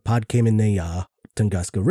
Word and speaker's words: Podkamennaya Tunguska: So, where this asteroid hit was in a not-so-populated area in the northern forest land above the Podkamennaya Tunguska River.